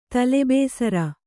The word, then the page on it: ತಲೆಬೇಸರ: kannada